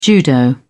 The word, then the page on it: judo: British English